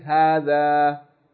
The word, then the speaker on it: هَذَا: All we're saying is that there are certain frequently used words in Arabic language in which the aleph in those words of elongation is not written, but you have to pronounce it. Okay, so you learn those words. Whenever you see them, even though you don't see no aleph written there, you pronounce it as though there's an aleph. Okay, you say, هَذَا, هَذِهِ, لَكِنَّ, إِلَاهٌ, like this.